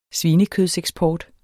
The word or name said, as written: svinekødseksport